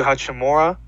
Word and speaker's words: Hachimura: They have Ryu Hachimura.